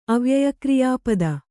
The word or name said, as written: ಅವ್ಯಯಕ್ರಿಯಾಪದ